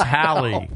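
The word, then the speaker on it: Halle: Yeah, you know, it's Holly Berry. Who's Holly Berry? – It's not Holly. It's Hallie.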